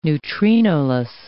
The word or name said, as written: neutrinoless